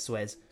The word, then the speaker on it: Suez: Suez.